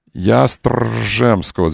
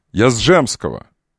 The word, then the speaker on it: Ястжемского: Теперь Ястржемского здесь РЖ. Ястжемского правильно будет по-польски. Р не читается ку-ку перед Ж. Р перед Ж по-польски не читается.